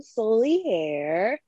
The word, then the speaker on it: Soliere: But hi, I'm Soliere. I am a poet extraordinaire, which is a new moniker that I am testing out that rhymes with my name and is stupid and I love it.